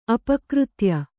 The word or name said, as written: ಅಪಕೃತ್ಯ